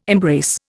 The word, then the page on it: embrace: Indian